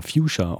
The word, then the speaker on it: Fuchsia: Eine der Bands, die eher in der ersten Stunde dabei waren, waren Füchser, also Füchser geschrieben, aber Fuchsia ausgesprochen von den Amerikanern.